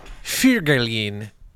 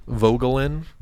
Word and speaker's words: Voegelin: You know, I think of someone like Eric Fugelin. That would be the correct German pronunciation. Eric Fugelin, V-O-E-G-E-L-I-N. Vogelin, I think, is the way most English speakers would say that.